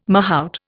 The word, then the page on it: mahout: Indian